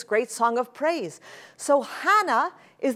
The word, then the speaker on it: Hannah: That name is Hannah. If you notice very carefully, this is an aspiration, and this means that you pronounce an H sound.